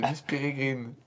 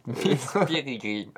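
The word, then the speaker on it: Miss Peregrine: Miss Piririni, Minigistiridili. – Non, c'est Miss Pirigrine. – Miss Pirigrine.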